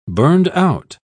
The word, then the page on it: burned-out: British English